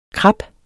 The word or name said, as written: krap